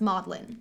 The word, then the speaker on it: Magdalen: Magdalen is pronounced Maudlin.